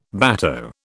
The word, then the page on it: bateau: British English